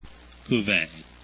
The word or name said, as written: cuvee